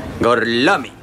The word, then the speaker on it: Gorlami: Gorlomi? Lo pronuncio correttamente? – Sì, è corretto. – Gorlami? Per cortesia, me lo ripeti ancora? – Gorlami. – Oh, mi scusi, com'è? – Gorlami.